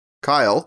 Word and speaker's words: Kyle: which is The Kyle, K-Y-L-E, because how else would you spell that?